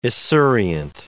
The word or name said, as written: esurient